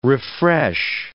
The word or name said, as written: refresh